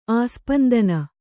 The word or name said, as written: ಆಸ್ಪಂದನ